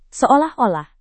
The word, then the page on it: seolah-olah: indonesian